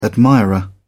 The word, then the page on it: admirer: British English